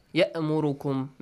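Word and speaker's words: يأمركم: And the next letter, hamza, ya'murukum. Make sure you do not mispronounce it, like ayn, ya'murukum. If you say ya'murukum, it means it builds you, construct.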